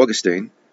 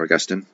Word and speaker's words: Augustine: In contrast to Augustine, or Augustine, whichever you prefer, I've pronounced it both ways, Polkinghorne imagines that humans have always been mortal and been subject to physical death.